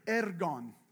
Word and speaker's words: ergon: It's pronounced ergon. Please do the tongue trill.